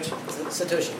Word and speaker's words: Satoshi: Satoshi Kanazawa, am I saying that right? – Satoshi, yes.